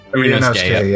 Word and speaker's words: Ryunosuke: We're playing, was it Ryunosuke? Was it? – Ryunosuke, yeah. – Yeah, Ryunosuke Naruhodo.